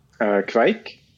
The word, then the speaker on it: Kveik: Kveik.